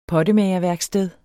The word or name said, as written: pottemager